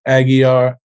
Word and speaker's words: Aguiar: The L is silent, right? Aguiar, our other co-founders of Until Freedom, Angelo Pinto, Linda Sarsour.